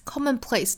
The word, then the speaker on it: commonplace: Don't be fooled by its 连读 by its 有一个小小耶来衔接 by its commonplace appearance. Commonplace 的重读音节在第一个音节上 commonplace. 很多同学可能会把这个音读成 commonplace，要注意一下啊，应该是 commonplace.